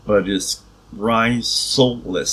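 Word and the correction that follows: rhizolysis: So it's RIZOLYSIS.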